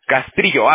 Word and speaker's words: Castrillo: ¿Castillo? Cristian. – Castrillo, TR, Castrillo. – Ah, Castrillo, ah, perdón, disculpe.